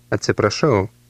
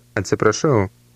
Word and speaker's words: Atsiprašau: Atsiprašau. Atsiprašau. – Note the rolling R sound in the middle of the word. How do you say "excuse me" in Lithuanian? – Atsiprašau.